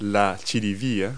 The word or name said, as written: parsnip